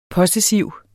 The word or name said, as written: possessiv